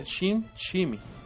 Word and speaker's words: SimSimi: Fue desarrollado en el 2002 por una empresa que se llama Eastmaker, la cual ahora se llama SimSimi la empresa se pronuncia shim shimi.